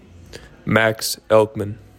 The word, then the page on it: Max Elkman: MAX ELK mihn